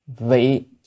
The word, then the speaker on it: vị: Vị, chữ vị chư hữu tình. Chữ vị này có nghĩa là vì, còn âm nữa là vi. Nhưng ở đây phải đọc là vị, vị mới là vì.